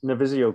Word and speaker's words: Navisio: Navisio Global.